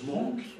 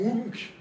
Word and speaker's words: Munch: Au gris, donc une peinture un peu monkienne, hein, parce que vous savez que Munch, ça se prononce monk, ou munk.